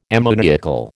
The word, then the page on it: ammoniacal: American English